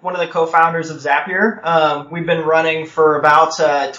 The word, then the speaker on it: Zapier: I am your host, Avdi Grimm, and joining me today is Wade Foster of, how do you pronounce it, Zapier? – It's, Zapier makes you happier is the trick. – Okay, Zapier.